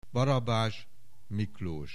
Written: Barabas Miklos

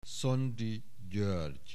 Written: Szondi Gyoergy

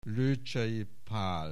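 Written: Lőcsei Pál

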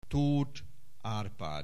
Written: Toth Arpad

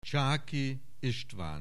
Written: Csáky, István

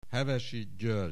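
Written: Hevesy, György

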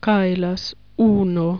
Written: kaila uuno

